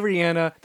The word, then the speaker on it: Rihanna: And yes, I do say Rihanna. That's how you say her name.